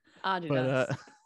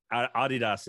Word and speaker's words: Adidas: I was just amazed at how you pronounced the word that I thought was Adidas. – Adidas. But, Adidas is how we pronounce it in England.